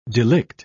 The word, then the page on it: delict: British English